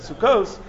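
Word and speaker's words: sukkos (סֻכֹּת): That's why we pronounce it sukkos, but the reason it's spelled sukkas is so that we can darshan it as a singular.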